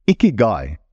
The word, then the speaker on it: ikigai: And thank you, Keanu, again, for helping me on the pronunciation of that Japanese word, ikigai. I hope I'm getting it right now.